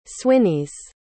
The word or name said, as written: swinneys